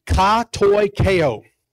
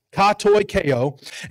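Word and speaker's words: katoikeo (κατοικέω): It's definitely not how it's spelled. It's just how it sounds. But it is katoikeo, katoikeo. And listen to this.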